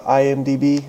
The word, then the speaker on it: IMDb: You're talking about IMDb. Is that how you pronounce it? – Correct. – It's letters, so that's definitely how you pronounce it.